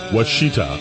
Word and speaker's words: Washita: There's another way I could pronounce this, but I'm not going to. It's spelled W-A-S-H-I-T-A. Wichita.